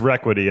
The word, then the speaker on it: Requity: it's Requity, and I see R-E, and I always say Re. So, yeah, so the Requity Group, R-E-Q-U-I-T-Y.